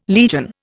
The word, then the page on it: legion: Indian